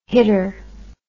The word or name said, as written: hitter